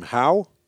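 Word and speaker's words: Hau: Want to go to, I don't know how to pronounce the name, Howe, listening in Fort Wayne, Indiana, WFCV.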